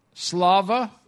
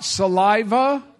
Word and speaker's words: слава: How do you say it in Russian? Slava. That sounds like something I ate a couple of days ago. Oh, not saliva? Oh, slava. Okay. I thought you said saliva, and I would, you know, I was eating.